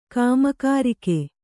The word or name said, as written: ಕಾಮಕಾರಿಕೆ